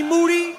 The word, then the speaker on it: Moody: This is G Moody. – Yes. – Last name rhymes with duty.